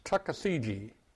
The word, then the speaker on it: Tuckasegee: It's spelled just like it sounds, Tuckasegee, Tuckasegee. See how simple that was?